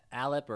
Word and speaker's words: Allip: Allip or Allip? I'm not sure how you say it. I've always said an Allip. – Allip. I say Allip.